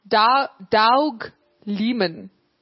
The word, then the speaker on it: Doug Liman: Doug Liman.